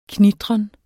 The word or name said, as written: knitren